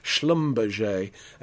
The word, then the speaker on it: Schlumberger: Yes, I said it correctly. Schlumberger, a